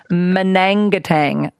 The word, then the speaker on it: Manangatang: Oh, I'm getting lots of texts on the text line telling me that it's Manangatang. No, I was going Mangatang. I left out a syllable. It's actually Manangatang.